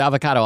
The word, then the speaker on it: avocado: We're going to talk a lot about the word avocado also, but apparently I've just learned from you that it's pronounced avocado. – It's like Chicago.